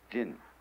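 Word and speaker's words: Jin (仁): How do you pronounce that in Japanese? Jin.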